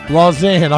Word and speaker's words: Lausanne: East Nashville 34, Waverly 20, Yoak 14, South Green 0, Bartlett 14, Brentwood nothing, Henry County 42, Portland nothing, Lausanne. How do you say it again? – Lausanne. – I'm getting it correct.